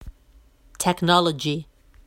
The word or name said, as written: technology